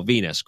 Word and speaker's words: venous: They should spell it like the planet, and then it would be easier for me to pick up on that. Okay, but now that I know, venous, chronic venous insufficiency during a medical exam.